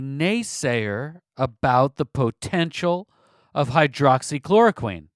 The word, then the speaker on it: hydroxychloroquine: It should be easy to go and seek out the studies of people using hydroxychloroquine. I know I pronounced it hydrochloroquine earlier. We're going to now pronounce it correctly.